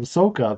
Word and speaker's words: Sokka: His name is incorrectly pronounced as Sokka, but that's the Asian pronunciation of it.